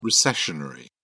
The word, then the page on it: recessionary: British English